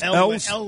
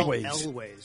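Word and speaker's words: Elwes: Elways. – It's number five there. Elways.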